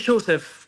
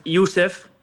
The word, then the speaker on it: Yusef: Me dicen Joseph, como que le pronuncian lo como se lee en argentino. En realidad es Yusef. – Ah. – Pero me dicen Joseph.